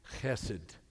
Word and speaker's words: chesed: chesed. Now, usually you might see it as H E S E D. In, in Hebrew, it's a het, so it's pronounced this, this guttural CH.